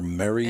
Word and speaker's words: Marre: Well, M-A-R-E is... – M-A-R-R-E. – Oh, in French. Well, it's pronounced... – Marais? – Mar. And it means fed up, apparently.